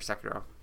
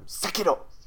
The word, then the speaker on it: Sekiro: If you want to, like, break your brain into saying it correctly, just say it like a... like a hardcore samurai every time. – Sekiro! Sekiro! All right. I got two into it there. – And just say the R is a D.